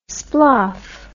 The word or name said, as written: sploff